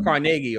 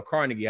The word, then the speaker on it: Carnegie: Dale Carnegie or Carnegie, however you want to pronounce it.